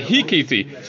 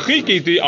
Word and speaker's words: Vechikiti: Apparently Rabbi Chiyah, he mispronounced Chet as Hayes. He couldn't say Chet. It's an old thing. – Oh, yeah, huh? So he said, right? So he said, oh, yeah, right. So he said, Hikiti. So instead of Vechikiti, I'll wait for Hashem. Hikiti, Hashem, I'll strike Hashem. So you'll be a blasphemer. So you know, go tell him. He's telling you a bad voice. Tell him he's an idiot because he doesn't pronounce the chet.